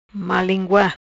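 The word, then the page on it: Malingua: patua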